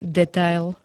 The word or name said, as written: detail